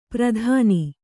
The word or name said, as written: ಪ್ರತಾನಿ